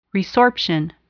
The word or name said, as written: resorption